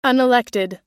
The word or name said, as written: unelected